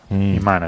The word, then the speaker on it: Iman: ¿Aimen? Ay, divina. – Iman. – O sí. Iman, bueno. – Iman, así se pronuncia.